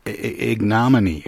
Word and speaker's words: ignominy: This is More Bad Days in History, The Delightfully Dismal Day-to-Day Saga of I keep wanting to say ignominy, but it's ignominy.